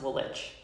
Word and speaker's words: Woolwich: Yeah, it's spelled Woolwich, but it's pronounced Woolwich.